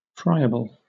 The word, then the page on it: friable: British English